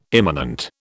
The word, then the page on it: imminent: British English